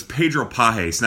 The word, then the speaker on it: Pagés: He spells his last name, or his last name is spelled P A G E S. Uh, but it's Pedro Pagés.